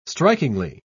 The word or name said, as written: strikingly